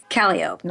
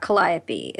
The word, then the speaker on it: Calliope: It's Calliope, not Calliope. It's Burgundy, it's not Burgundy.